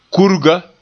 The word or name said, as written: kurga